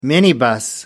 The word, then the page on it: minibus: American English